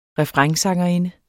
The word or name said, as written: refrainsangerinde